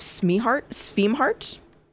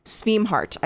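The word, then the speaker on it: Scimhart: And Joseph Sveemhart, S-C-I-M-H-A-R-T, I think it's... Sveemhart, I think is how you say it.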